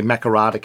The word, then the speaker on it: Makarrata: How you say it? We're doing our best. – We're doing our best. – Makarrata? – Makarrata. Yeah, the Makarrata Commission.